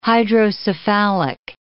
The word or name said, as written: hydrocephalic